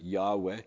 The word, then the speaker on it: Yahweh: In Hebrew, it's just those four letters. We usually pronounce it Yahweh if we try to pronounce it in English.